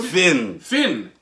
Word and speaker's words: Finn: Finn. Like a dorsal fin. – Oh, okay. – F-I-N-N.